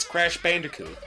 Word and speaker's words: Crash Bandicoot: where they almost remade the entire games and threw in a bunch of extra bonus content just because, hey, it's Crash Bandicoot, you know? – Thank you for pronouncing that correctly.